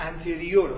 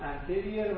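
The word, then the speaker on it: anterior: و اینا رو اگه بگه انتریور و پوستریور کاملا غلط، تلفظ انتریر و پوستریر، قدامی و خلفی.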